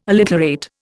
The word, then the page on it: alliterate: Indian